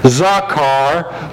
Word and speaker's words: zakar: The word in the original language in Hebrew is zakar, Z-A-K-A-R. You pronounce it like zaw, Z-A-W-K, zakar.